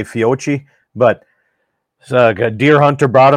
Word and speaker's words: Fiocchi: Is that how you pronounce it? – I think it's Fiocchi actually, but, but yeah, that stuff.